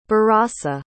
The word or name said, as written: barossa